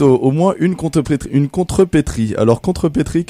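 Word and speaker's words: contrepèterie: Ça s'écrit contre et ensuite péterie avec un E, mais on ne prononce pas le deuxième E, donc péterie en fait, mais pétrie.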